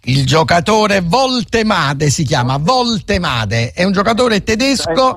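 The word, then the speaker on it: Woltemade: Valtemade è semplicissimo perché la W si legge V in tedesco, come sta scritto così lo leggiamo, non facciamo errori, Valtemade.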